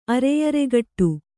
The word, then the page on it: ಅರೆಯರೆಗಟ್ಟು: Kannada